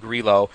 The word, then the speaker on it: Grillo: Because he's, everybody thought it was Grillo because he's from Brazil, but he's actually Italian. – Yeah. So Grillo. One shot back at -9. So Rose and Grillo, the defending U.S.